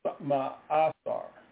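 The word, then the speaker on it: ma'aser: Ma'asar. M-A-A-S-E-R. M-A-A-S-A-Y-R.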